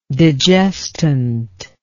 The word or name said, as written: digestant